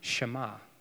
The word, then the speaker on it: Shema: And it's called the Shema because that first verb is pronounced Shema.